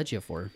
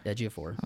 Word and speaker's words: Ejiofor: You're going to say that last name for me one more time. Do you know, is that how it's pronounced? – Chiwetel Ejiofor. – Ejiofor? – Ejiofor. – Okay. Now I know.